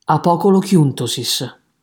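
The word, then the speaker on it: Apocolocyntosis: Si intitola Ludus de morte Claudii cioè Scherzo sulla morte di Claudio, più nota con il titolo greco di Apocolochiuntosis. Un greco strano a dire la verità, pronunciamola di nuovo insieme. Apocolochiuntosis.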